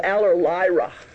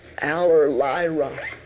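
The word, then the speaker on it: Allerleirauh: Because the proper name is difficult to pronounce, and so we put in what was easier, but it's called Aller Lyra. Aller Lyra.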